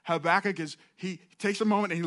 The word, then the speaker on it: Habakkuk: You can pronounce it a couple different ways, all right? Habakkuk, I'm going to choose Habakkuk. It's got 3 K's in it, just in case anybody's wondering.